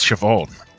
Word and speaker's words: Siobhan: Now, this caused all kinds of conflict for Siobhan. I think that's how you pronounce it. Is that right? – Yes, it is, Siobhan.